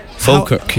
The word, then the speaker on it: Falkirk: And I think you're coming from a town called Falkirk. – Yes, is that true? – Falkirk, yes. – Falkirk. – You're saying it right, you're saying it right.